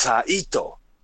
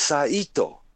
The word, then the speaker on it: Saito: And a lot of people really like a Saito, Saito. No, my name is Saito. And in wrestling, Mr. Saito was Mr. Saito. Therefore, they did not mispronounce my last name either. I'm thankful. See, in school, they were calling me Saito, Saito, right? No, my name is Saito. It's different.